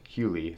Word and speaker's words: Hugley: Some other guys on the visit list: John Hugley, the fourth, Hughley, it's pronounced, but it's spelled Hugley, of Pitt, visiting this weekend as well.